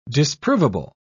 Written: disprovable

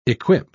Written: equip

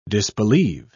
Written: disbelieve

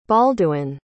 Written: balduin

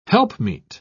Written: helpmeet

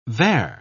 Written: vair

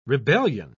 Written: rebellion

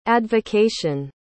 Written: advocation

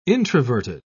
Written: introverted

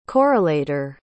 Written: correlator

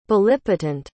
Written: bellipotent

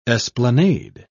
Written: esplanade

